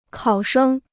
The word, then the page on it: 考生: kǎo shēng